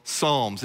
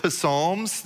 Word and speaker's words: Psalms: I want to go to the very middle of God's word to a book that we call Psalms. It's a book of songs, and some people pronounce it psalms. They think they're the, it's psalms, but uh, the P is silent as it always should be in our lives.